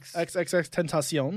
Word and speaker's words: XXXTentacion: XXXTentacion.